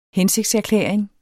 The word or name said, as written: hensigtserklæring